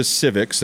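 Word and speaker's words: Civiqs: In Colorado here, Biden's approval rating, according to civics, that's spelled with a Q, C-I-V-I-Q-S, you can look this up yourself, is dead even 46-46, and we know what a blue state we live in here.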